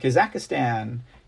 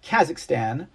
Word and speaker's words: Kazakhstan: Yeah, Kazakhstan. I apologize. I need to pronounce that correctly. Kazakhstan.